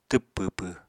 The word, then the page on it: twp pwpw: Apatani